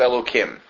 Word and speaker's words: Elohim: When we see it following the word as we have here, then it's pronounced Elohim.